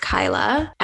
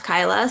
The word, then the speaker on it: Kayla: I was actually going to ask because, so my name is spelled Kayla, but it's actually pronounced Kyla. And So my whole life I've been having this battle of, you know, it makes sense because it's spelled Kayla, but it's pronounced Kyla.